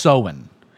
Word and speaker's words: Samhain: So it's actually spelled S-A-M-H-A-I-N, but it's pronounced Samhain.